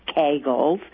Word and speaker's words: Kegel: The history of the Kegels, actually I read that it's pronounced Kegels. I read that. K-A-Y-G-U-L is the phonetic.